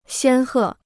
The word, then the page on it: 仙鹤: xiān hè